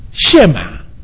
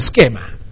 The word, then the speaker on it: Shema: Shema. And some people pronounce it Schema.